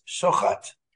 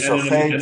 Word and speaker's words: Shuchat: I have to say that in Israel, we pronounce our name as Shuchat. – Oh, okay. Okay. – Originally, it was Shuchat. – In America, it's... – But in Canada, it was Shuchat because the CH comes out like the French.